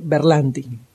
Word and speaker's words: Berlanti: que son Greg Bernalti, Michael Green y Mark Guggenheim. – Greg Berlanti. No, Berlanti.